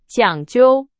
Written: 讲究

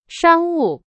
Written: 商务

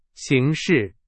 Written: 形势

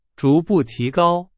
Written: 逐步

